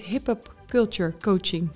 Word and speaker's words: Hiphop Culture Coaching: En ehm, dat heet hip-hop Culture Coaching.